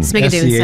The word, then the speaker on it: Schmigadoon: Part of Sprigadoon. – Sprigadoon. – Sprigadoon. Did I say it right? Yeah. One of the Schmigadoon. – Schmigadoon. I knew I didn't say it right.